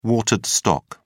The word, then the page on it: watered stock: British English